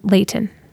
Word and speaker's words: Layton: You pronounced Layton correctly. – That's good. – You can tell you're from California. I like that. It's not Layton. – My kids. – It's Layton.